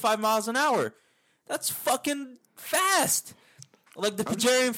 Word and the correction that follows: peregrine: falcon can fucking dive up to 200 miles an hour. – Peregrine falcon, huh? – Peregrine falcon, whatever.